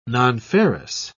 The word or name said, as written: nonferrous